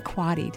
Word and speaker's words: Quade: We're talking with author Dana Quadi. Did I say it correctly? – Very good.